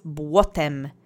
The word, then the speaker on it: błotem: And the last word is błotem. It means mud. It's a noun in the neutral singular object form. Let's say błotem together three times. Accent is on the first syllable.